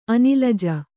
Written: ಅನಲಜೆ

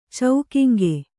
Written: ಚೌಕಿಂಗೆ

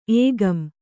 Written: ಏಗಂ

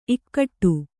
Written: ಇಕ್ಕೋಡು

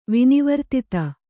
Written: ವಿನಿವರ್ತಿತ

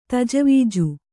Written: ತಜವೀಜು

